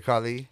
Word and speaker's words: Khali: No, when they were seeing who was going to fight first, and when he bumped into the great Khali, did I say it right? – Yeah, the great Khali.